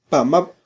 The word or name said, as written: pamab